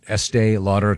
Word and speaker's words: Estee Lauder: Is it pronounced Estee Lauder? Estee Lauder, yes.